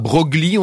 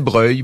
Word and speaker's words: Broglie: On disait pas Broglie, on disait Breuil.